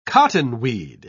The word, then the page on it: cottonweed: British English